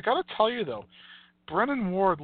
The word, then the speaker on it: Brennan Ward: They all called him Brandon Ward, but it's Brennan Ward, not Brandon Ward.